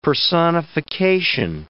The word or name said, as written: personification